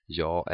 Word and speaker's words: jag: It's pronounced like ä, and the word jag is almost always pronounced ja.